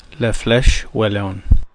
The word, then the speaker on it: La Flèche Wallonne: So the first is the race that we talked about on a very recent Fredcast, and that is what I called La Flèche Wallonne. Here, of course, is the correct way to pronounce that famous race. – La Flèche Wallonne.